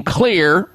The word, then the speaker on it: Xlear: And that is to make sure that you keep your nasal cavity moisturized, detoxified, and clear using Clear Rescue Nasal Spray. But the word clear has a different spelling to it than would be classically known. Clear is spelled X-L-E-A-R. The X stands for xylitol.